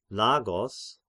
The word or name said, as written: Lagos